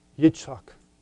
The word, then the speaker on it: Yitzchak: The Hebrew pronounced Yitzchak.